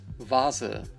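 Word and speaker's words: Vase: Ну и в единичных словах, которые, как правило, легко узнать и понять интуитивно, что они означают, может читаться как «в». Вазе.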